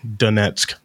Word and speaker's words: Donetsk: They'll recognize kind of a rogue government and immediately recognize it, which is what they did in Luhansk and how do you pronounce it? – Donetsk. – Thank you.